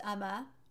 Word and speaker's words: Uoma: It's spelled UOMA, but it is pronounced Uma.